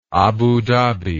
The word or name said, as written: Abu Dhabi